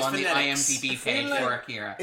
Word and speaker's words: Akira: It's Akira. I believe the A is pronounced.